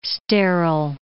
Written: sterile

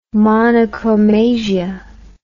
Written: monochromasia

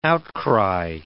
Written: outcry

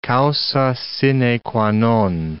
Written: causa sine qua non

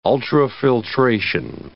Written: ultrafiltration

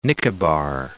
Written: Nicobar Islands

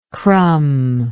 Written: crumber

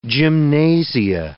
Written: gymnasia

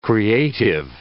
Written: creative

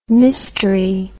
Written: mistery

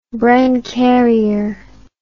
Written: bren carrier

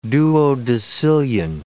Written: duodecillion

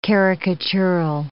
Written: caricatural

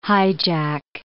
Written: highjack

